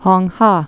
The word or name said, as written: Hong Ha